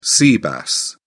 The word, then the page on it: sea bass: British English